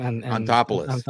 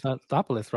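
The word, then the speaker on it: Anthopoulos: Antopoulos. – Antopoulos, right? – He came from the Dodgers.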